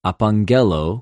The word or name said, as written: ἀπαγγελλω